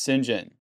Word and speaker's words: St. John: So St. John Smythe, the reason why it's pronounced St. John is because it kind of goes back to Norman French origin. So they would used to say like St. Clair would be Sinclair. So the sin would be Saint.